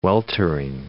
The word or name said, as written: weltering